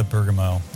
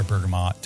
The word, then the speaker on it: bergamot: But yeah, that's the bergamot or bergamot, depending if you pronounce that tea at the end of it, which I've seen some people do, some people not do.